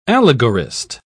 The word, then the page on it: allegorist: British English